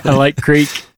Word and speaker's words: creek: Have you thought about, you know, maybe changing your pronunciation? – Nope. I'm sticking to it. I like creek. Creek, stream, river. There's no cricks in my vocabulary.